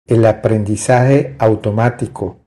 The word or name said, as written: El aprendizaje automatico